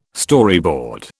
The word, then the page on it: Storyboard: British English